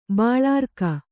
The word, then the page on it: ಬಾಳಾರ್ಕ: kannada